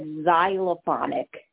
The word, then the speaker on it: xylophonic: Well, first of all, just to let you know, Naomi, we have X is actually pronounced like the letter Z, so it's xylophonic.